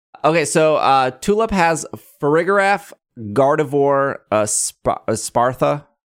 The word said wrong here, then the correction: Espathra: How do we say this? – Espathra. – Espathra, and then a Florges.